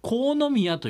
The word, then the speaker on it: 国府宮: 国府宮と書いて国宮と読むそ